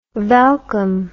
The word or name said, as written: welkom